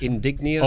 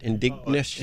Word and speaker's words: indigenous: indigenous. Oh, is that it? Indignous.